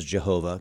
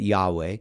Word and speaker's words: Yahweh: Yahweh is his name from the Hebrew text, and so sometimes mispronounces Jehovah because we misunderstood vowel pronunciation a couple hundred years ago, or vowel replacements, but Yahweh.